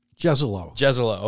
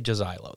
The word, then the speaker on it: Jezylo: I'm going to pronounce his name wrong. – Jezelo. – Okay, that's what somebody else was telling me, but yes. I want to say Jezilo, though. It looks like a Jezilo.